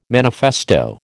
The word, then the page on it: manifesto: American English